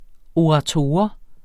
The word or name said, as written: ORATORER